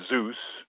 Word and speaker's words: Zeus: Then there's the EU sound, which in the word Odysseus sounds like two syllables, but in Zeus sounds like one syllable.